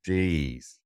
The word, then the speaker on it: Mercedes: If you say the word Mercedes in your head, it is one of the only words where every E is pronounced differently. Have a think about that. Mercedes.